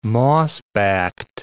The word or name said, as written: mossbacked